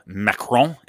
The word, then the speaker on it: Macron: President Emmanuel Macron. Is that who you're talking about? – That's great. That's a great way to pronounce that.